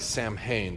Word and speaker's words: Samhain: And speaking of the Scots, the Celtic reference of Irish and Scottish children guising, please allow me to clear up any confusion regarding Samhain. That's how you pronounce S-A-M-H-A-I-N. It looks like Samhain, but it's Samhain.